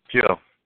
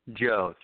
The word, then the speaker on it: Joe: Joe. – Will you spell that, please? – Joe, J-O-E.